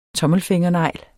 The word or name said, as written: tommelfingernegl